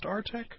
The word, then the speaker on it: Artek: Is that how it's pronounced, Artek? – It is indeed. – And, A-R-T-E-K.